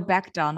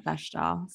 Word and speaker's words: Bechdel: Oh, Bechdel, not Bechdel. Sorry, I was reading okay. it phonetically.